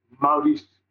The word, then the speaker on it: Maurice: Well, I mean, I call you Maurice, but it's like the correct pronunciation is what? – Maurice. – Is the R like more like a Spanish R, or is it more like a French R? – I don't know what Spanish R is.